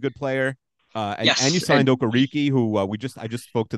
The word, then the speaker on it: Okereke: Everyone's calling him Okereke, and apparently he said it's pronounced Okereke.